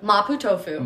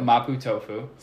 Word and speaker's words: mapo tofu: Mapo tofu. – Mapo tofu.